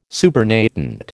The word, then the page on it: supernatant: American English